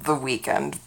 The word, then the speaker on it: The Weeknd: Somewhere in the description, but I was listening to Often by I'm pretty sure it's pronounced The Weeknd, but it's not spelled Weeknd, but I gotta tell you this much.